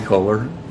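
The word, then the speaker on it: Koller: Callie Kohler.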